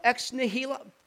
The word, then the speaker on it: ex nihilo: Ex hilo, ex nihilo. How does that go? How do you say that word? Ex nihilo.